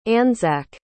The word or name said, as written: anzac